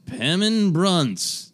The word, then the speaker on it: Pemmin Brunce: First combatant in this first round episode is Pemmin Brunce.